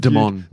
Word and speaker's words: Damon: It's pronounced Damon. – Damon. – Yeah.